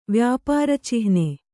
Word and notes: ವ್ಯಾಪಾರಚಿಹ್ನೆ: vyāpāra cihne

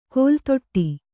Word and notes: ಕೋಲ್ತೊಟ್ಟಿ: Kannada

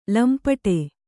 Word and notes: ಲಂಪಟೆ: lampaṭe

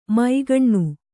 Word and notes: ಮೈಗಣ್ಣು: māgaṇṇu

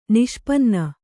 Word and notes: ನಿಷ್ಪನ್ನ: kannada

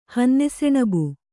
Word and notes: ಹನ್ನೆಸೆಣಬು: hanne seṇabu

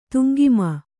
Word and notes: ತುಂಗಿಮ: kannada

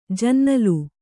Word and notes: ಜನ್ನಲು: Kannada; jannalu

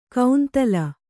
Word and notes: ಕೌಂತಲ: kannada

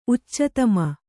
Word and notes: ಉಚ್ಚತಮ: uccatama

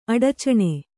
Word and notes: ಅಡಚಣೆ: Kannada

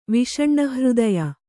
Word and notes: ವಿಷಣ್ಣಹೃದಯೆ: kannada

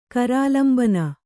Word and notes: ಕರಾಲಂಬನ: kannada

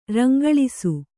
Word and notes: ರಂಗಳಿಸು: rangaḷisu

